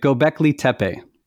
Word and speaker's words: Göbekli Tepe: Göbekli Tepe. Is that how you say it? – Right. Göbekli Tepe. – Perfect.